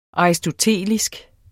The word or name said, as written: aristotelisk